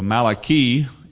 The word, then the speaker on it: Malachi: If we said angel, we would say Malach. So Malachi is the way correctly pronounce it, or East Texas correctly pronounce it, you know.